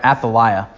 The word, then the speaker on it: Athaliah: And so Athaliah sees her chance, or Athaliah, I think that's the way that most English speakers pronounce it. I think in Hebrew, when I read it, I'd pronounce it like Athaliah or Athaliah, but there's a kind of a weird letter in Hebrew that's more like a t, like a more hard T.